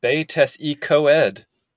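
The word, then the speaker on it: Betws-y-Coed: There's the town of Betesikoid, which the book wants you to pronounce as Betesikoed.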